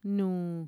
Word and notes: nuu: Mixtec

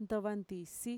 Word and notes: ntovantisi: Mixtec

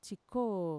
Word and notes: txikoo: Mixtec

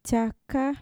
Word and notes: txaka: Mixtec

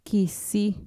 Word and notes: kisi: Mixtec

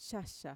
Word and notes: xaxa: Mixtec

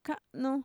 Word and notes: ka'nu: Mixtec

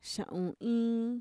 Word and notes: xa'uniin: Mixtec

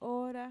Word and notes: oora: Mixtec

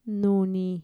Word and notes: nuni: Mixtec

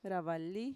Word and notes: ra-vali: Mixtec

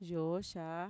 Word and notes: yooxaa: Mixtec

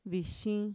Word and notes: vixin: Mixtec

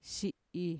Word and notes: xi'i: Mixtec

